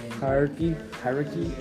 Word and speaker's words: hierarchy: How do you pronounce this word? Hierarchy? – Yeah.